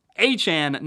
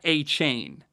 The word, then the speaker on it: Achane: We now know it's Devon Achane, not Achane. Like Jackie Chan, not like, Chain Gang or something.